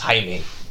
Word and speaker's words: Jaime: It's spelled like Jamie. – Yes. – But it's pronounced Jaime.